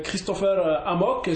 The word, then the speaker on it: Christopher Amok: Voilà donc, bonjour Christopher Amok, j'ai bien prononcé le nom? – Oui, c'est une prononciation en français, oui.